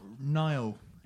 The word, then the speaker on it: Nihil: Nile is okay. Pronounced. Right. There you go.